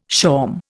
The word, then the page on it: shawm: Indian